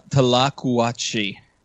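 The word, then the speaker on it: tlacuache: Tolache? – No. – Gulache? – Tlacuachi. – Tlacuachi. Okay.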